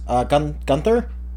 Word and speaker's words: Gunther: So I was playing, this was in the singles event, it was against Chris Gunther? – Yep. – I hope I pronounced his last name right. Yep. – Yes, you are.